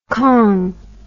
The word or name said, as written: kong